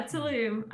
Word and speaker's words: Tulum: Is that how you pronounce it? Tulum. – Yes. To Tulum. I think there's like a little at the end of it. Yeah, Tulum.